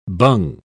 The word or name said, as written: bung